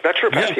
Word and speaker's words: naturopath: Naturopath, is that how you pronounce it? Or naturopath? – That's right. Absolutely. Naturopath, yes.